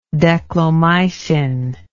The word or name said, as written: declomycin